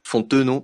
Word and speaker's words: Fontenot: moi, c'est Jeff Fontano. Fontenot, pardon, excusez-moi pour euh, la prononciation.